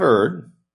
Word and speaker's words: erred: If ye have erred, that's actually the correct pronunciation of that, by the way, in case you were wondering, and not observed all these commandments which the Lord has spoken unto Moses.